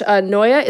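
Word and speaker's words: Neu: She has gone on to star in HBO's Entourage, published Noia. Is it Noia Sex or New Sex? – Noi. – You can say both. – Noi Six. – It's spelled like Ne-U.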